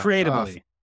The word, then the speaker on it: Creatably: Oh, so sorry. Creatability. I just butchered that. – Creatably.